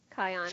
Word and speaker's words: Kion: The new magic shots include Kion. – Kion. – From the Lion. Thank you. Okay, that rhymes with lion.